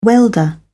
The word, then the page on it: welder: British English